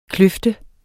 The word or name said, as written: kløft